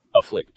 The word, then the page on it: afflict: American English